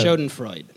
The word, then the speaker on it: Schadenfreude: Schadenfreude, is that how you pronounce that? – Yeah. Schadenfreude.